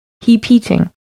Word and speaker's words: hepeating: So it's H-E-P-E-A-T-I-N-G. Heapeating. – Okay. That's not what I thought it was going to be. It sounded to me like heap heating,